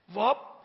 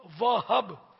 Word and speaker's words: وہب: لغت میں ہا ساکن بھی ہے اور ہا مفتوح بھی ہے، یعنی زبر والا بھی ہے. وہب وہب دونوں تلفظ اس کے درست ہیں.